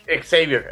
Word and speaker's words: Xavier: Se pronuncia Javier o Xavier. – Xavier, el profesor Xavier.